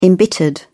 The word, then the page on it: embittered: British English